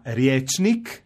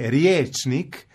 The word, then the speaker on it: riječnik: Pa, na primjer, u riječima riječnik, vjesnik, ja ću izgovoriti kratko jer je to pravilno, međutim većina ljudi će izgovoriti riječnik, vjesnik, izgovorit će dugo i onda će pomisliti izgovara se dugo, dakle piše se ije, što nije pravilno.